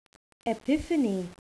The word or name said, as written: epiphany